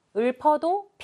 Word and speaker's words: 읊어: 읊어도 피읍 올려주세요. 읊어.